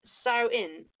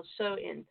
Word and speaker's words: Samhain: And named Samhain, I think that's how it's pronounced, Samhain or Soin, in which people wore costumes and would light a bonfire to ward off ghosts.